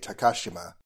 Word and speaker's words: Takashima: So Ivanova, if she were pronouncing it more according to Russian pronunciation rules, would be Ivanova, and Takashima would be Takashima.